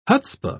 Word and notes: hutzpah: British English

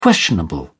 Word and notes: questionable: British English

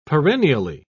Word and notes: perennially: British English

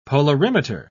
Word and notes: polarimeter: British English

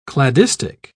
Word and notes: cladistic: British English

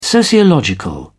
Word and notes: sociological: British English

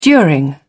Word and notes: during: British English

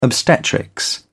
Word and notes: obstetrics: British English

